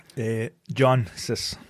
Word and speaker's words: Jancsics: Jansis. – Jansis. – It's pronounced, it's spelled J-A-N-C-S-I-C-S. – Yeah. Yes. – But C's are silent. – Okay. – So it's just pronounced J-A-N-S-I-S.